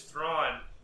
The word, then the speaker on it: Thrawn: It was Thrawn, who rhymes with Tron.